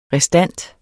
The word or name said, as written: restant